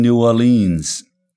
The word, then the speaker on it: New Orleans: Wenn wir von New Orleans mit der Betonung auf der zweiten Silbe sprechen, dann halten wir uns an die Aussprache, wie sie die Jazzmusiker und Songwriter seinerzeit verwendet haben. Way down yonder in New Orleans, heißt es in einem alten Schlagerlied, und Do you know what it means to miss New Orleans?